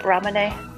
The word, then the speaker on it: Ramune: Is that like the Ramune thing or Ramune? I don't know how you pronounce it. Ramune.